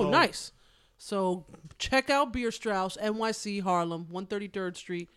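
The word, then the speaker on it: Bierstrasse: 133rd and 12th is spelled in a German fashion. It's B-I-E-R S-T-R-A-S-S-E. – Beer Strauss. – Beer Strauss.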